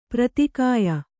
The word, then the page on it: ಪೂರಿತಗೆಯ್: Kannada